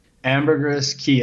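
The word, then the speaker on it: Ambergris Caye: Ambergris Cay. It's spelled like a key, like a key to open a door.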